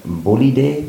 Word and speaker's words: Bolide: Wir haben es auch äh, auf YouTube schon kurz angekündigt, das neue Pinarello äh, Bolide, wie es ausgesprochen wird, mhm. habe ich jetzt gelernt.